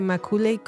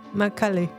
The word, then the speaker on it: Macaulay: Maculey, Maculey Makula. Macula. Macaulay.